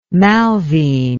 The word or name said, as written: malvine